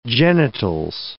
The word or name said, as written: genitals